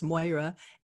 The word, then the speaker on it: Moira: It's Moira, M-O-I-R-A.